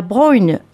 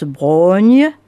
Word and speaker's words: Braunhie: La Brogne, alors que ça s'écrit B-R-A-U-N-H-I-E, la Brogne, dites-vous. Nom hirsute et bizarre au premier regard, mais familier à tous les Quercinois. On le prononce Brogne, et se lèvent alors des chaînes sans fin, tenaces, tordues, une dense forêt venue tout droit d'avant, même l'histoire.